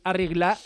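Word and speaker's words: Al Rihla: Bueno, que ya se ha desvelado también el balón del Mundial, el Arigla, así se pronuncia, significa el viaje, y bueno, no difieren mucho a cualquier balón que hayamos visto en cualquier fase final últimamente.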